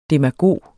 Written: demagog

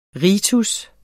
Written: ritens

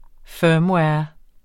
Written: firmware

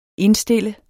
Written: indstille

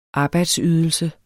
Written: arbejdsydelse

